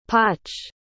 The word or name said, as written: poche